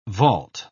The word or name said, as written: vault